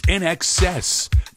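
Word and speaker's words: INXS: When they first came out, you see all the letters and you're like, how do you pronounce that? It's In Excess, part of this best of the 80s and 90s weekend.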